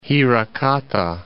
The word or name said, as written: Hirakata